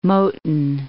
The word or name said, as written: Moton